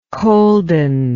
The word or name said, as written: colden